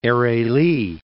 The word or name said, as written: Eregli